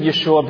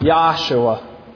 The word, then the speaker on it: Yeshua: It is not Yahshua. It is not now, nor has it ever been pronounced Yahshua.